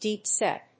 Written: deep-set

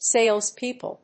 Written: Salespeople

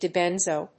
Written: dibenzo

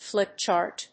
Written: flip chart

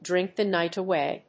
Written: drink the night away

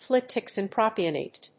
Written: fluticasone propionate

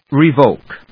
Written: revoke

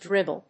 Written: Dribble

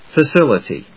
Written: facility

